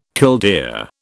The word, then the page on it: killdeer: British English